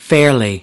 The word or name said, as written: fairly